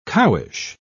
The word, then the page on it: cowish: British English